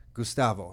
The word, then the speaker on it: Gustavo: Gustavo, am I saying your name properly now? – Gustavo. Yeah.